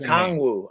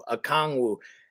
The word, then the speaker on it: Okongwu: Yeah, I, um, I don't Kong, like Okongwu. – Okongwu.